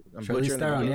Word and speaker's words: Charlize Theron: Charlize Theron. Is that right? – Charlize Theron, yeah.